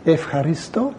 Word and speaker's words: ευχαριστώ: They pronounce the EU a bit differently in modern Greek, but if you say ευχαριστώ, means thank you.